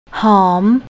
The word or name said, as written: hom